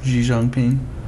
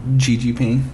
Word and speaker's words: Xi Jinping: That's not how you say his name. – How do you say it? – Xi Jinping. – What did I say? – Xi Jinping. – I said Xi Jinping.